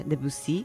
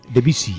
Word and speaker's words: Debussy: Y ya sabéis que en Gitanjáfora, cuando suena La Mer de Debussy, es que va a compartir un cuento propio, ¿no? – Un cuento propio, qué bien pronuncias, ¿eh? Debussy. – Debussy.